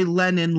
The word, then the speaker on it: Lennon: So I would have to say Lennon like John Lennon, or I'm saying Lennon, but I'm like, am I saying it too fast?